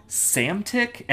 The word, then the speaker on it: Psamtik: Also, Psamtik, and that's also spelled P-S-A-M-T-I-K. – Silent P's? – Yeah, like, I mean, what, like... – That's a power move. That's a silent P? – Yeah, Psamtik.